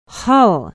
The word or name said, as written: hull